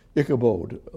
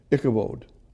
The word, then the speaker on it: Ichabod: Ichabod, long O. Ichabod.